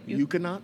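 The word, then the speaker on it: Ekuanot: Eukanot is the way I've always pronounced it.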